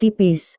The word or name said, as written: tipis